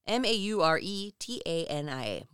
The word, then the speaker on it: Mauretania: I think it's the Mauretania room. M A U R E T A N I A. Mauretania. – That sounds, we're going with.